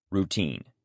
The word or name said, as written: routine